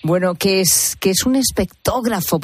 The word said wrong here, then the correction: espectrógrafo: por favor? Espectrógrafo, ¿cómo se dice?